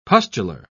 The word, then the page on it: pustular: British English